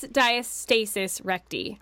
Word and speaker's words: diastasis recti: Is it recti or recti? I never know how to say it right. – I think it's diastasis recti. – Oh, there you go. Diastasis recti.